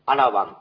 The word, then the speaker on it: アラワン: アラワンって言うんですけど、ラの、アラのラのところが、小さい感じで発音してください。